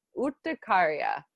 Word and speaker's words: urticaria: The Spanish word and the medical term for hives is urticaria. It's spelled U-R-T-I-C-A-R-I-A, which makes it sound way uglier.